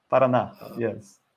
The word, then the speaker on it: Paraná: Jirlani Hickman, I believe right now she is at the Federal University of, I hope I'm pronouncing it correctly, Paraná? – Paraná, yes.